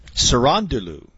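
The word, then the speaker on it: Cerúndolo: The way they say it on the broadcast, it's Cherundolo. – Cherundolo.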